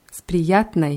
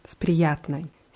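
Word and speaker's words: Sprijatnai (с приятной): Spriyatnoi. – Sprijatnai. Ja, die Endung ist immer oi, aber weil sie unbetont ist, wird sie eher als ei ausgesprochen. Sprijatnai.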